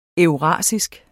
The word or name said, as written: eurasisk